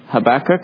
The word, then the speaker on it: Habakkuk: Habakkuk, and that's how I'm going to pronounce it.